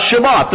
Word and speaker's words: Shabbat: But do you realize the emphasis in which God put upon the Sabbath, the Shabbat, as it's pronounced in Hebrew, and not polluting the Shabbat, which is from sundown on Friday till sundown on Saturday?